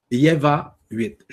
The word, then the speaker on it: Yeva 8: il se dit Yeva 8. Je vous l'appelle Y-E-V-A 8, le chiffre 8. Et il s'écrit encore, je vais vous le répéter, Y-E-V-A 8.